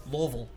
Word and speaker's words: Louisville: And Dayton Dutch Lions versus Lovell Zone River City Rovers. So those are did you say Lovell? – Louisville. – Louisville.